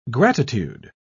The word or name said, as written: gratitude